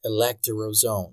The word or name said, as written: electoral zone